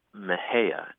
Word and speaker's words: Mexia: We have a funny town here called Mejia, and it's spelled M-E-X-I-A. – Mm. – And I think if you're an out-of-towner or a Yankee, you might be tempted to say Mexia, right, mm-hmm.